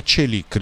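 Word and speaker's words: Çelik: L'ho voluto dedicare a Celik, ricordatevi sempre questa pronuncia, da parte del mio amico turco, nonché collega, Dundar Kesapli, che è una sorta di turco italiano, non voglio dire turco napoletano come il film di Totò, però un paravento anche lui, ormai sono 25 anni che in Italia, mi ha detto si pronuncia così, punto, non te lo dico più, mi ha detto, va benissimo.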